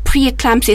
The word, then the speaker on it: preeclampsia: and it's called preeclampsia. Is that right, Dr. Aisha? Am I pronouncing it right? – That's right.